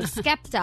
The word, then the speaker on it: Skepta: Rumor has it that Adele and Spekta are dating. – Oh, I love Spekta. – No, wait, I said it wrong. Skepta.